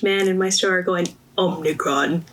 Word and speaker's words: Omicron: Omarion virus, and I was like, what is this called?